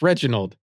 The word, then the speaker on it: Reginald: It's Reginald.